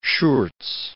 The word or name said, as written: Schurz